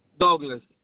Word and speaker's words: Douglas: Ok, primer nombre, Douglas, como lo pronuncian los americanos.